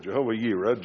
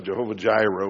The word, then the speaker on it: Jehovah Jireh: I think I said Rohi before. Jehovah Yireh, Jehovah Jireh, we usually pronounce it.